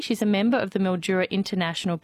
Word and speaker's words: Mildura: Mildura, not Midura.